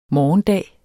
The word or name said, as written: morgendag